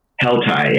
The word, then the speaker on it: Heltai: Heltai, yeah.